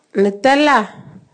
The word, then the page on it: Natafoa: Naka